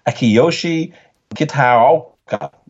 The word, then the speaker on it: Akiyoshi Kitaoka: The concept's been done, but it's developed from an original photograph by an experimental psychologist called Akiyoshi Kitaoka. That's exactly right. How you say his name, too, by the way. That was perfect.